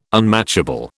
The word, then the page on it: unmatchable: British English